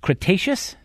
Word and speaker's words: Cretaceous: This was 66 million years ago, the end, what is it, Cretaceous? Is that how it's pronounced? – Yep.